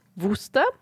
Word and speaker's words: Worcester: Ähm, Wuster. Mhm. Wie von einer Wustersoße. Glaube ich, wird es ausgesprochen. W-O-R-C-E-S-T-E-R.